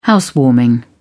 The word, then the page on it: housewarming: British English